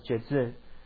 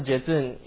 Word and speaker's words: Jetsun: So the word venerable in Tibetan is pronounced as Jezun. So Jezun, you can spell as J-E-T-S-U-N, Jezun.